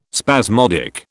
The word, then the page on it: spasmodic: British English